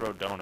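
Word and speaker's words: Rodón: This is a little off-topic, but during the Carlos Rodon, it's pronounced Rodon, apparently. I didn't think it was pronounced that way, but I've been corrected now. It's Carlos Rodon.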